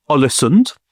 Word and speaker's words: Ålesund: His family are actually big in the business of exporting dried and salted fish in a town called Ålesund, which I know is the correct pronunciation. I visited last year, went there on a family holiday to Ålesund, absolutely gorgeous town up on the Norwegian coast.